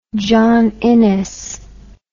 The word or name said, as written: john innes